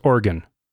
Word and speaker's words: Oregon: Pronounced just like the ones in your body. – No, there's an E in there. Oregon, not Oregon. – It's pronounced Oregon.